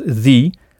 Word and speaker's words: the: Ça montre que vous avez d'ores et déjà assimilé ce que je vous expliquais un peu plus tôt, à savoir que l'article the se prononce the quand il est suivi d'un son voyelle, comme c'est le cas ici avec economic.